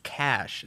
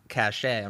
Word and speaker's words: cache: The other day, Jamie told me, hey, just so you know, it's cash. It's not cachet. I'm not trying to embarrass you, but it's pronounced cash.